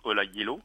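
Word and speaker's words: Ola Gjeilo: Alors, il se prononce Ola Jello. – Ah, raté.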